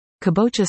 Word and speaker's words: kabocha: Some of the varieties available included acorn, spaghetti, butternut and kabocha, the latter of which I purchased. Kabocha, pronounced ka-bo-cha, squash is a popular variety in Asian countries, such as Thailand, Korea and Japan.